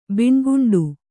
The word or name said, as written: ಬಿಣ್ಗುಂಡು